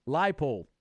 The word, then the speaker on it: Leipold: It's Lance Leipold. We got to get that right. If we want to hire that guy, we got to get his name right. Leipold.